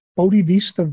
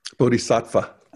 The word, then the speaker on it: Bodhisattva: And that's right, part of our job at Integral, the Bodhisattva vow. Did I say it right? – Bodhisattva.